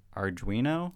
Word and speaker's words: Arduino: class, where each student is given, I hope I'm saying this right, Arduino? – Yes.